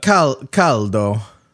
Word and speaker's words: caldo: Caldo. – Is it caldo? – Caldo. I believe so. You want me to check? – Yeah. We don't have to check right now. – Hot in Italian. Caldo.